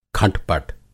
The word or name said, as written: खटपट